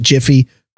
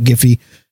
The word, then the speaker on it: Giphy: They probably pronounce it Jiffy, but frankly, that's the name of a popcorn. So I'm going to call it Giphy.